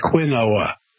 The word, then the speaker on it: quinoa: Now, it's spelled Q-U-I-N-O-A. It looks like quinoa.